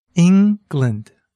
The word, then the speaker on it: England: Try saying the word England. England. Can you hear where the stress is? It's on the first syllable, ing.